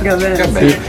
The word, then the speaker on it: Gaben: Gaben. – Io ho studiato inglese e francese, lo studio al momento che mi si presenta l'occasione. – Esattamente come l'attore, il famoso attore Jean Gabin.